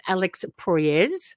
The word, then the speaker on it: Proyas: It's Alex Proyas.